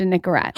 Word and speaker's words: Nicorette: Next time you come on, the first question I'm going to ask you is, are you addicted to Nicorette? – Wait, how do you say it? – Did I say it right? – Nicorette. Yeah. Nicorette gum.